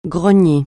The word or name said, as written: grogner